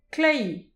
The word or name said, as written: kleiz